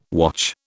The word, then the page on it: watch: British English